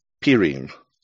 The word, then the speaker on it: Purim: You can pronounce it many ways. In Eastern traditions, it's called Purim. Among Westerners, it's also called Purim. And some Central European communities even call it Pirim.